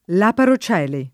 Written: laparocele

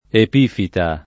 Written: epifita

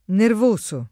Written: nervoso

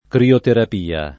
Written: crioterapia

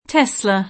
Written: Tesla

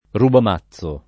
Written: rubamazzo

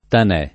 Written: tanè

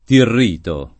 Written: Tirrito

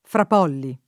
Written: Frapolli

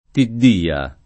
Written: Tiddia

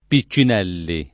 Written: Piccinelli

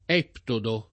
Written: eptodo

